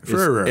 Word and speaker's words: Frewer: Frewer. – F-R-E-W-E-R.